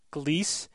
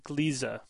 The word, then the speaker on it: Gliese: Um, actually, at, at, the time, I pronounced the name of the star Gliese, and I said then the planet or Gliese 581, and the and this new planet would therefore be Gliese 581g. But I was informed by a listener that it's actually a German word, and it's supposed to be pronounced Gliese, as two syllables, not one.